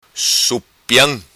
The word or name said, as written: Supiam